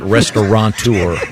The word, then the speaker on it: restaurateur: I know that the most mispronounced is restaurateur. – Mm-hmm. – There's no N. It's not restaurateur.